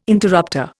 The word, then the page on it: interrupter: Indian